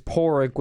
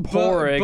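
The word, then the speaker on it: Pádraig: Well, but you know, Patrick in Irish is Porrick, which is spelled like P-A-D-R-A-I-G. Just a little fun fact. – That's weird. – You're thanks weird. For ruining the fucking show! – It looks like Padraig, but it's pronounced Porig, but it's Patrick.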